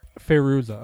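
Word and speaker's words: Fairuza: It's close enough. I think it's Firuza. – Firuza, okay.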